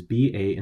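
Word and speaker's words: 把: 筷子 is K-U-A-I in the fourth tone and Z-I with no tone, and that means chopsticks. – Then we have ba, which is B-A in the third tone, which is the measure word for things that can be grasped by a handle, for example, forks. So that's pronounced ba.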